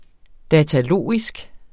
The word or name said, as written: datalogisk